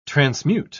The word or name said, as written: transmute